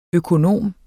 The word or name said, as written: økonom